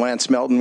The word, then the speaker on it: Lance Melton: Mr. Chairman, members of the committee, for the record, I'm Lance Melton with the Montana School Boards Association. My last name is spelled M-E-L-T-O-N.